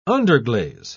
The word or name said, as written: underglaze